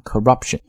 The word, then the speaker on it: corruption: corruption 这个单词就可以表示腐败和贪污， corruption 重音呢落在 rup 上面啊， corruption。